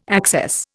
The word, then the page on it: access: Indian